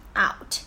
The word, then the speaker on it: out: 今天啊，我们要去学习的呢，其实是一个很简单的一个词，就是 out， OK，拼写就是 out， out。那大家一定要注意一下啊，这个 out 它的一个发音呢，在这边的 ou 发的是一个双元音 ow，所以大家在发音的过程中呢，一定要记住，我们要从啊慢慢的过渡到 ow，OK，有点像是一个小豹子的发音，对吧？ow，OK，so out，一定要注意第一个音的时候那个啊。